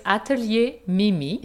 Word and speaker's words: Atelier Mimi: Um, there's a gallery on Magazine Street, and you might have to pronounce it for me because I will mispronounce it, being that it is in French. so it's Atelier Mimi.